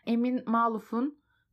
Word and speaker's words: Amin Maalouf: Arapça Emin Maluf diye okunuyormuş.